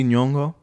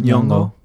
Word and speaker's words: Nyong'o: Is that how you said Nyong'o? – Yeah. – What about her? – Soft G Nyong'o. – Oh, thank you. Do it again. – Nyong'o.